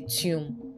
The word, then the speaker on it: tomb: Alright, so the B in that word is silent. So it's a tomb.